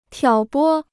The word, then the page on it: 挑拨: tiǎo bō